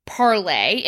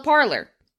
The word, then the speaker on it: Parler: And it was parlay. And actually part of their, when you make posts, they're called parlays. But the thing is that Americans joined up. The CEO was like, wow, no one's pronouncing this correctly because we're in America. So now they just call it parlor because all of the xenophobic users.